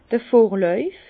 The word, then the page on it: d'r Vorläuf: Bas-Rhin; Herrlisheim